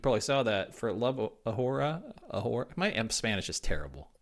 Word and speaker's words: ahora: It's pronounced ahora. The H is silent.